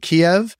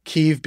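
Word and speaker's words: Kyiv: My understanding is it's Kiev, not Kiev. Kiev being the Ukrainian pronunciation of the capital city.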